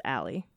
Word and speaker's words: Ali: I know somebody really, really close to me that spells it A-L-I and it's pronounced Allie.